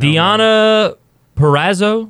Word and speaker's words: Purrazzo: Deonna Purrazzo. Is that how you say it? – Yeah.